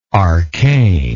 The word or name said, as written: arcaneness